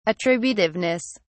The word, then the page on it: attributiveness: British English